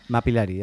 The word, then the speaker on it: Mapillary: estaba colaborando con un proyecto sueco sé que se llama Mapillary o Mapillary o Mapillary, ya no sé cómo pronunciarlo. – Mapillary, ya que, te yo, Mapillary.